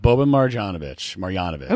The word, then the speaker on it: Boban Marjanovic: Boban Marjanovic.